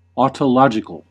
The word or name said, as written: autologisk